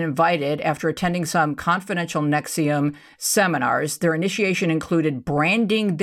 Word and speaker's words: NXIVM: This particular group, Nexium, first of all, they are horrible spellers because Nexium is spelled N-X-I-V-M.